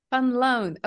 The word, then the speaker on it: van Luyn: So it's a Dutch surname, actually. So not far from where you are. It's Van Luyn. If you pronounce it like a Dutch person, though, as my dad would, because he hates the, you know, English pronunciation, it's Van Loon.